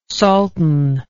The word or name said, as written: salten